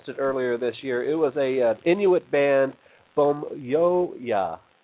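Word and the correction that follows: Pamyua: I believe is how you pronounce it. – Pamya.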